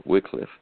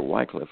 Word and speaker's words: Wycliffe: By the way, that is the preferred pronunciation, is Wycliffe. You hear Wycliffe a lot of times, but that is the pronunciation.